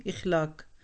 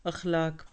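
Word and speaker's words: अखलाक (اخلاق): इखलाक कहते हैं हमारे यहाँ लोग, इखलाक नहीं अखलाक। अफ़आल का वज़न होता है खुलुक से, अखलाक पुराना होना।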